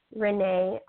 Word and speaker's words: Renee: So it's Yvette Renee Isaias, and I'll spell it. It's Y, B as in Victor, E as in egg, T as in Tom, E as in egg, Renee, R-E-N as in Nancy, E-E, I, S as in Sam, A-I, S as in Sam.